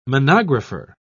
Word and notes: monographer: British English

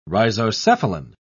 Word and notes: rhizocephalan: British English

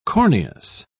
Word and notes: corneous: British English

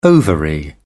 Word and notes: ovary: British English

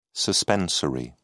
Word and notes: suspensory: British English

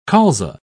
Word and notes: colza: British English

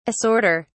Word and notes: assorter: British English

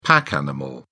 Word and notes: pack animal: British English

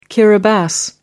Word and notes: kiribati: American English

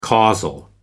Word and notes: causal: American English

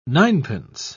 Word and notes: ninepence: British English

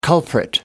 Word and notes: culprit: American English

